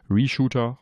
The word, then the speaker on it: Reshoot R: Ich habe jetzt gelernt, es wird Reshooter ausgesprochen, also Reshoot R geschrieben.